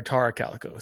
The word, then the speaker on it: Tara Calico: Um, am I nervous to cover certain stories like Terra Calico? Uh, yeah, or Tara Calico. Sorry, I said it wrong.